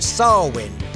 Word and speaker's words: Samhain: Well, it was Ireland, and they called it Samhain, but it's not spelled like that, S-A-M-H-A-I-N.